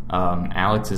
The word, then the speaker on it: Alex: Alex is spelled A-L-E-X. McNeil is spelled M-C-N-E-I-L.